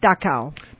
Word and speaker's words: Dachau: Dachau.